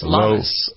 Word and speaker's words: Lammas: Today is Lamaze Day. Is that how you pronounce that? L-A-M-M-A-S? – Takes I don't its think it's name Lamaze. I think it's Lamas, isn't it? – Is it Lamas?